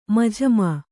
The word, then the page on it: ಮಜಮಾ: Kannada